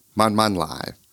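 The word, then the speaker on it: man, man, lai (慢慢來): Man, that's pronounced with a fourth falling tone. It means slow. Double up the word, man, man. It means like really slow. Lai, second tone. That means to arrive.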